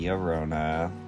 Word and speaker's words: Llorona: And the entirety of episode 2, I was saying La Llorona wrong because I was pronouncing the two L's in Llorona, but it's actually Llorona, like with a Y.